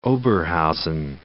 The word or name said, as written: Oberhausen